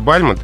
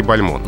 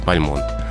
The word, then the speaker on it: Бальмонт: Мне приходилось слышать, и в некоторых словарях и энциклопедиях дается «Бальмонт». Хотелось бы рассказать, почему все-таки «Бальмонт». – Ну, потому что, во-первых, французская. – И потому что сам поэт произносил свою фамилию с ударением на последнем слоге.